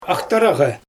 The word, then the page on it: axtaraGE: Xyzyl